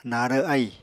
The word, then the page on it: narw ahì: Apatani